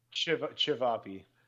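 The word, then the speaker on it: ćevapi: Where we had Javapi. – Nope. – How do you pronounce that one? – Chivapi. – Chivapi. Okay.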